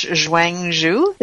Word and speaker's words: Chuang Tzu: And forgive me about the pronunciation, but I believe it's Chuang Tzu.